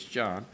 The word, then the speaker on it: Jean: The one said, Brant Jean, it's spelled like Jean, but it's John.